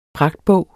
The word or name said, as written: pragtbog